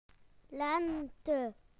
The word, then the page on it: spring: British English